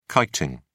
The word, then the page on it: kiting: British English